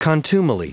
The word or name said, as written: contumely